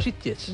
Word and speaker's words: Sitges: No ha dicho Sitges, ha dicho Sitges. – Lo ha dicho muy bien. – Lo ha dicho muy buena pronunciación catalana.